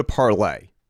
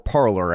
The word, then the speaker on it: Parler: why don't you go to Parlay, or Parler, as it's pronounced by some people? – Yeah.